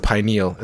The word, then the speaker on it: pineal: Oh, that's the pineal. It's spelled. – Pineal. Yeah. Is it spelled differently?